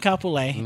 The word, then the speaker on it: Kapolei: We went I went to Kapolei. – And your family is dead to us. – Yeah, I think that's how that's pronounced. – Yeah, that's definitely Kapolei.